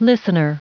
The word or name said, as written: listener